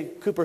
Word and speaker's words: Cowper: For instance, we went to Alney, England, and in Alney, you had the Alney hymns written by Cowper, spelled Cooper, but he is pronounced Cooper, but spelled Cowper, and John Newton.